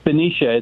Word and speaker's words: Benicia: Benicia, California. Is that how you say it? Uh, yeah, it's Benicia. It's, uh, Benicia.